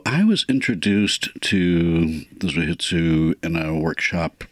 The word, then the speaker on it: zuhitsu: Some of the poems in this book take the form of zuhitsu. Is that how you pronounce that? – Zuhitsu, yeah.